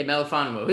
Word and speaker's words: Melifonwu: Yeah, Melifonwu.